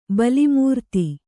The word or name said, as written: ಬಲಿಮೂರ್ತಿ